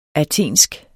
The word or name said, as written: athensk